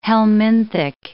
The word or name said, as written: helminthic